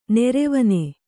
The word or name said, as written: ನೆರೆವನೆ